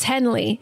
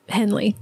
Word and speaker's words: Jenle: It is directed by Lars von Trier, written by Lars von Trier, and I think this is pronounced Henley. It's J-E-N-L-E, but it's like, I presume, Danish. Lars von Trier is Danish, and it just seems like it would be pronounced Henley to me. So should I name my daughter that? What is it? – J-E-N-L-E.